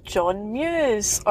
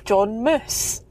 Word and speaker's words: John Muse: Our new goalie is John Muse, or as Ian had told me for the last 24 hours, John Moose.